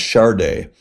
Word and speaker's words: Sade: It's spelled Sade, pronounced Sade.